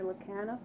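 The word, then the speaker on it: Lekanoff: My name is Mary Lacana.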